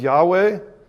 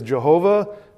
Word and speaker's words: Yahweh: On the mount of the Lord, capital L-O-R-D, the tetragrammaton, the four letters that we pronounce as Yahweh. Some people have pronounced that alternatively as Jehovah. I think Yahweh is probably more correct, but ultimately what we know is that's the name of God, going all the way back to the burning bush.